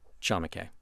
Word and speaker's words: Chamakay: It's actually called Chamaque. – How do you spell that? – C-H-A-M-A-K-A-Y, Chamaque.